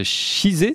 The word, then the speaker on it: Chizé: Vous êtes herpétologue, directeur de recherche CNRS au Centre d'études biologiques de Chizé, c'est comme ça qu'on prononce ? – Oui.